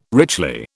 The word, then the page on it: richly: British English